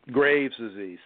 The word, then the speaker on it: Graves' disease: You said it's called raised disease. How is that spelled? – Graves' disease.